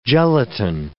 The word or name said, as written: gelatine